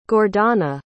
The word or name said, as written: gordana